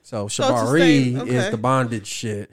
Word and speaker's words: Shibari: So it is called Shabari. It's spelled Shibari.